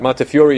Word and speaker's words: Montefiore: topic for tonight is the life of Moses Montefiore, or Moses Montefiore, depending on how you, how you want to pronounce his last name. But Montefiore is the correct pronunciation.